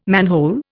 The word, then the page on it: manhole: Indian